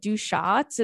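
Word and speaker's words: Deux Chats: Oh, wait, before I get to this email, I met a few girlies at this place that I love called Du Shots. It's like, and listen, I'm not pronouncing it right because that's in French, but it's D-E-U-X-C-H-A-T-S.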